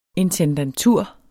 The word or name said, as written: intendantur